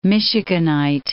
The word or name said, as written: Michiganite